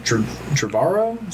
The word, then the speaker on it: Trevorrow: I think they should have, because, you know, they had JJ Abrams planned, Rian Johnson, and then Colin Trevorrow. Is that how you pronounce his last name? – Yeah.